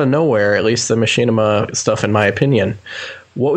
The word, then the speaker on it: Machinima: How do you actually pronounce this? Is it Machinima? – Machinima. – Okay, I never actually knew that.